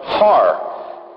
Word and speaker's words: Har: That's actually a breathing symbol, which means a breath symbol, which means that it's pronounced har, with an H type of sound.